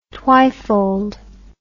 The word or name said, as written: twifold